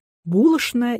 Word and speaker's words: булочная: Кто-то до хрипоты спорит, что правильно говорить «булочная» и «дожди», а в ответ слышит, что так говорили только манерные дамочки, а надо «булочная» и «дожди». Нет бы заглянуть в орфоэпические словари и узнать, что и то, и другое верно.